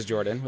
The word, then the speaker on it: Jorphdan: My name is Jorphdan, with a silent PH in the middle, and I'm joined, as always, by my wonderful co-host, Sir Lucian, over at Sir Lucian Gaming.